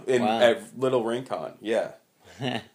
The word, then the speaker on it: Rincon: Was it Rincon or Rincon? – Rincon. I always pronounce it Rincon. – Rincon. Yeah.